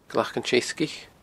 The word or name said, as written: Glac an t-Seasgaich